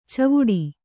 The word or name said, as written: ಚಾವಡಿ